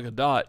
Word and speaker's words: Gadot: On Bronson Pinchot, you don't pronounce the T. On Gal Gadot, you do.